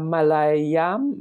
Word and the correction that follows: Malayalam: Ma, no, ma, how do you say it? Malayalam? – Malayalam, Mal- I think it is. Malayalam, yes.